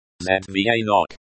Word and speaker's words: звонок: Pronounced Zvonok.